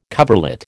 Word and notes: coverlet: American English

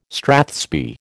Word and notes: strathspey: American English

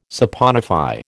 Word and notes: saponify: American English

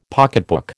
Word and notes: pocketbook: American English

